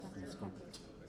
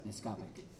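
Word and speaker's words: Nescopeck: And some of these territories down this way, like in the Neskopek region, is that how you pronounce it? Neskopek? Neskopek? Yes, Neskopek. So, Neskopek.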